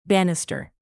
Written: banister

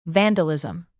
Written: vandalism

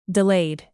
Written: delayed

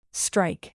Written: strike

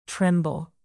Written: tremble

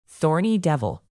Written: thorny devil

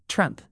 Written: trump

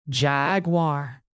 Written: jaguar

